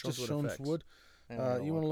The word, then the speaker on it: Schoenswood: At Schoen's Wood. Okay. Schoen's Wood is spelled Schoenswood. Schoen's Wood.